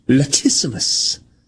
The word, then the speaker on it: latissimus: Somebody said I pronounced it correctly. Thank you. Latissimus.